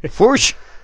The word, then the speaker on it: Fuchs: No sé si lo pronuncio bien. – Fuchs. – Estilo Rajoy, correcto. Bueno, y este hombre era un físico nuclear allí en Alemania que se afilió al Partido Comunista.